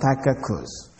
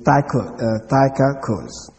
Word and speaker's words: Tychicus: But I had a C, another C in the name Tychicus. So I had another C, so I pronounced it Tychicus. But it's Tychicus.